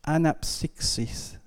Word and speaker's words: Anapsixis: Ja, aber ich wollte eigentlich äh, Matthias begeistern, weil das griechische Wort ist Anapsixis. Wunderbar ausgesprochen, wunderbar heißt Erholung, Aufatmen und Erquickung.